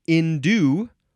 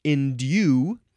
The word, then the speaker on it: endue: Next is endue or endue, and you can say the first syllable like in or n, but it's spelled endue, D-U-E.